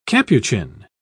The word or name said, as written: capuchin